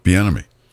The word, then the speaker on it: Bieniemy: Bieniemy.